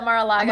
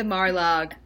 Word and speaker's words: Mar-a-Lago: Oh my God, is that what insiders call it? Mar-a-Lag? They say almost all of the syllables, but just lop off just that one last O.